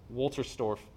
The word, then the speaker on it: Wolterstorff: Nicholas Wolterstorff, that's how you pronounce his name, lost a son.